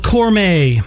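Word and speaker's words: Cormae: You're listening to 90.9 WCDB Albany, and that was a band called Cormay from Austin, Texas. That's spelled capital C-O-R, capital M-A-E.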